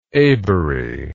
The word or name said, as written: Abury